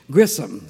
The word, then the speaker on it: Grisham: And in Iuka, our family name still had the English pronunciation without the H, Grissom.